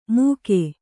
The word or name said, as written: ಮೂಕೆ